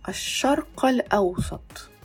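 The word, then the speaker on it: Аш-шарк аль-аусат (الشرق الأوسط): Но если мы будем использовать слово «восток» как географическое название, например, «Middle East», да, «Средний Восток», то мы будем произносить именно «каф». «Аш-шарк аль-аусат».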